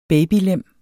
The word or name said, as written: babylem